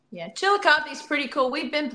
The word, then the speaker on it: Chillicothe: Chillicothe? Yeah. – Chillicothe, Ohio. – Chillicothe, Ohio.